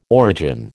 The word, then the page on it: origin: American English